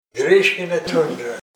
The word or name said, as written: Grishkina tundra